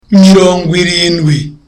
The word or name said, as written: mirongwirindwi